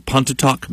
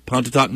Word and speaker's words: Pontotoc: Weatherly was born in Pontotoc, Mississippi. – Uh-huh. – Did I say it right? – Yeah. – Pontotoc, Mississippi.